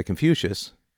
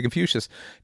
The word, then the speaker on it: Confucius: Okay, so I would say to Confucius, with an emphasis on the first syllable con, I would say to Confucius, never impose on others what you would not choose for yourself.